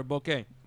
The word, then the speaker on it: bokeh: And he means bokeh, like bokeh, like the camera lens, right? – Oh, is that how you say it?